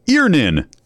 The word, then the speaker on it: Earnin: E-A-R-N-I-N is pronounced Earnin.